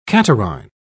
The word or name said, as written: catarrhine